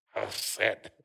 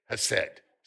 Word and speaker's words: hesed: And it means, it's pronounced hesed, actually. Hesed. You know, H-E-S, I'll stop trying. All right, hesed, say hesed.